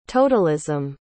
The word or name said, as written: totalism